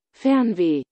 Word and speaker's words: Fernweh: I believe it's pronounced Fernweh. – Right, should I play it? – Yes. – Fernweh. – Fernweh. Okay. That was kind of close. – You were close.